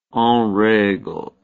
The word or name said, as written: enregle